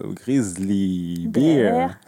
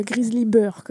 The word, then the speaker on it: Grizzly Bear: À chaque fois, je fais exprès de le prononcer mal pour énerver Pauline, en fait. – Là, ça va, parce que des fois, tu nous fais Grizzly Burr, quand même.